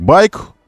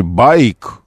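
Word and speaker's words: BAIC: Как байк, произносится, типа мотоцикл. Но на самом деле байк, ик, вот, байк.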